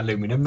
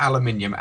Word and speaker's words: aluminum: Also, you're right about aluminum. That's how it's pronounced. – What? – It was originally aluminum, and it was changed to aluminium. And in fact, the Americans are correct. It is originally it was pronounced aluminum.